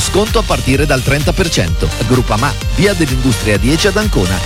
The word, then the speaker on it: Groupama: Groupama, si pronuncia Groupama, di Paolo Felicetti.